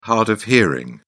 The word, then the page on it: hard-of-hearing: British English